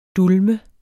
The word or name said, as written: dulme